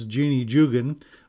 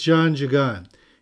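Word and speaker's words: Jeanne Jugan: Well, St. John Jagan was their foundress, and that's the French pronunciation. If we look at it, we probably say her name was Jeannie Jugan, but it's Jeanne Jagan.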